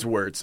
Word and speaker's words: Wirtz: Is it Wirtz or is it Wirtz? – It's Wirtz. Well, okay.